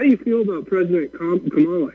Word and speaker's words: Kamala: And then, of course, this cackling nowhere witch woman, Kamala, I think is the way it's pronounced. – That's how you pronounce it.